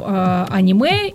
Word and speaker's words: аниме: Мы говорим об аниме или об аниме. – Аниме. Да. Кто как ставит ударение.